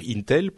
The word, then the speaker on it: Intel: J'ai dit Intel, je voulais dire Intel pour ceux qui parlent de prononciation.